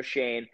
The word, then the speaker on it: Schoen: That's how you say his name, Joe Schoen. – Schoen.